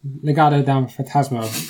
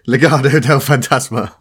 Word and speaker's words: Legado del Fantasma: No. Legado del Fantasma.